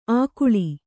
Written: ಆಗುಳಿ

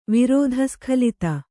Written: ವಿರೋಧಸ್ಖಲಿತ

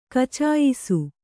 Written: ಕಚಾಯಿಸು

